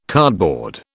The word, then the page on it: cardboard: British English